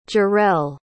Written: gerrell

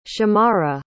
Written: shammara

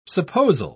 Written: supposal

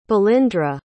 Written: balindra

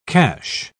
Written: cache

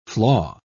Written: flaw

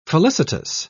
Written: felicitous

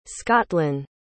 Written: scotlyn

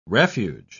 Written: refuge

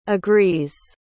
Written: agrees

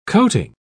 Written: coating

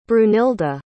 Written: brunilda

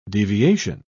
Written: deviation

